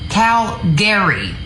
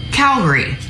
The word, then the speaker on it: Calgary: A lot of people not from there, or especially in rural places, will pronounce it Calgary, as in Gary the person with Cal also. No, it's actually Calgary. Calgary. You take out that A. No Garys are here.